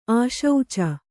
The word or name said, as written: ಆಶುಚಿ